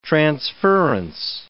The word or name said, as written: transference